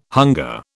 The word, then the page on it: hunger: British English